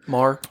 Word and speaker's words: Maher: Well, I picked Lord, I Need You by Matt Maher, if that's how you pronounce it. – Marr. – Matt Marr. – Yeah. – That's what I said, pretty much. But Matt Maher.